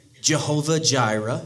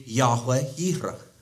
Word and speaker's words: Yahweh Yireh: That's fine, but properly, Old Testament biblical Hebrew, current day modern Hebrew, no hard J sound, so it wouldn't be Jehovah Jireh, it would be Yahweh Yireh.